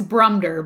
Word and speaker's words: Brumder: Am I pronouncing that right? – I think it's Brumder, but we'll Brumder.